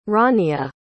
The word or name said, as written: Rania